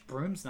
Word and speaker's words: Broom: who they spell in the credits B-R-O-O-M, because that's how you pronounce it, but not how it's spelled in the comics.